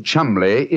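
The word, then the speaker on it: Cholmondeley: Being an American, he had no reason to know that the name Chumley is in no way pronounced the way it is spelt. – But you know, I never thought of that. Chumley. That name spelt C-H-O-L, Chow, M-O-N, Mon, D-E-D-E-R, L-E-Y.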